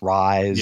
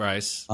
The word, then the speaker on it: Rhys: I don't know how to pronounce his first name. R-H-Y-S, Rice. – Yep, Rice.